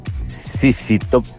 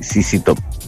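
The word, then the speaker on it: ZZ Top: Sissi Top, se pronuncia así, Sissi Top, nada más que eso, Zetita, saludos.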